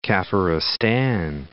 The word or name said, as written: Kafiristan